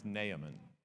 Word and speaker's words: Naaman: And the way I've heard most people pronounce his name is Naaman.